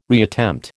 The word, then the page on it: reattempt: American English